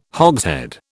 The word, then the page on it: hogshead: British English